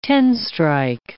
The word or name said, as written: ten-strike